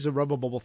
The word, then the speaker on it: Zerubbabel: We'll save Zerubbabel for the end.